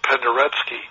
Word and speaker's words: Penderecki: It's spelled Penderecki, but I think they pronounce it Penderecki.